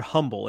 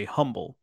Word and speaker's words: Humble: The word humble. And that's my point, is that people who don't know would say humble, but it's pronounced humble.